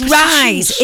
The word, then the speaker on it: Rys: Now, it's Rise, if you're listening, by the way. It's not like Rise as in Rise Up. It's like as in Ryan. You know, like if you said like R-I. – It's spelled R-Y. Y. – It's spelled... – No, R-Y-S. – Yeah. Rise R-Y-S.